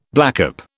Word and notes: blackcap: British English

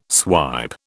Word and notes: swipe: British English

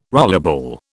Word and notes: rollerball: British English

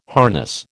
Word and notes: harness: American English

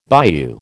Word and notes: bayou: American English

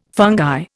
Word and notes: fungi: Indian

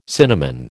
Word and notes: cinnamon: American English

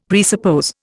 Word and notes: presuppose: Indian

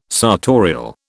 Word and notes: sartorial: British English

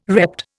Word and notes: repped: Indian

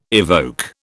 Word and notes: evoke: British English

